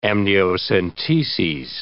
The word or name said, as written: amniocenteses